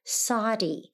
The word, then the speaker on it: Tsadhe: The next stanza, it's uh, difficult to know how to say that, but in Hebrew you would pronounce it Saudi like you would Saudi Arabia, Saudi.